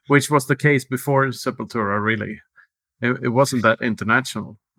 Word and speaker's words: Sepultura: I grew up with a band from Brazil, Sepultura, of course, the big, like, Sepultura, or as my friend Andre Lorja would say, Sepultura, which is how they pronounce it in Madeira.